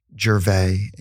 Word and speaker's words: Gervais: Both of them are my name, Michael Gervais. And you spell it G-E-R-V as in Victor, A-I-S.